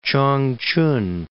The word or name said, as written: Changchun